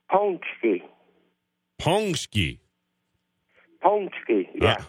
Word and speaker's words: paczki: Ponsky.